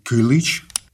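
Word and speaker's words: Kilis: Итак, был перевалочный пункт на южной границе, это город Килис или Килыч, как угодно, там разные варианты произношения.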